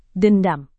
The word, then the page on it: dendam: Indonesian